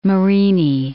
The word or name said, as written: Marini